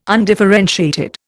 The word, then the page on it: undifferentiated: Indian